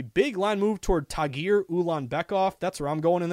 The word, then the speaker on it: Ulanbekov: Tagir Ulanbekov. Ulanbekov. U-L-A-N-B-E-K-O-V. Tagir.